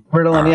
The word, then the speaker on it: Ritalin: is that how you say it, Ritalin? – Ritalin, yeah.